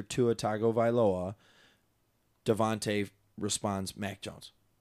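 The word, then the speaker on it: DeVonta Smith: I'm going to go with Devontae.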